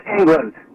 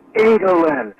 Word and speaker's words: England: Hillman, it's pronounced England, not England.